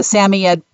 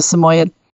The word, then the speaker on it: Samoyed: Also known as a Samoyed, pronounced correctly, but most people know it as a Samoyed.